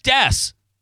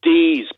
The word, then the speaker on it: Dees: It's Rick Dess. – Rick Dees, guys. – I'm being lured. – I'm looking at it right now. It's Dess. – He pronounced, I don't care how it's spelled, that's how he pronounced it. – How? – And you're saying it's pronounced how? – Dees. Look him up. He was a famous DJ in...